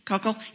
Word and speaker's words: Koukl: Our group is studying uh, the curriculum from Stand to Reason, which is Greg Koukl's uh, curriculum. Did I say that right, Dan? Koukl? Yep. Um, and you put those together, um, and we had the fire under our butts.